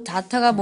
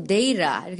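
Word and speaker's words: data: 재밌는 게 데이터란 단어를 미국은 데이라 이렇게 굴리는데 영국은 진짜 완전 T를 장렬해 주셔야 돼요. d 타 정말 그대로 d 타 이렇게 발음하거든요.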